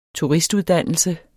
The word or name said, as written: turistuddannelse